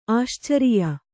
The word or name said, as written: ಆಶ್ಚರ್ಯ